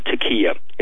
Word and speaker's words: taqiyya: I just pronounce it taqiyya. It's T-A-Q-I-Y-Y-A. I'll spell it again. T-A-Q-I-Y-Y-A.